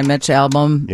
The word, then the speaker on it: Mitch Albom: Six People You Meet in Heaven by Mitch Albom. – Yeah. – Which I always say his name wrong. Did I say it right? – Yeah, you got it.